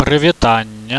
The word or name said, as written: pryvitannie